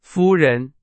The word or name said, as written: 夫人